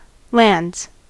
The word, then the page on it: lands: American English; /lændz/